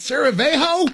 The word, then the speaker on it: Sarajevo: Is it Sarajevo? Yeah, I'm glad you can say it like that.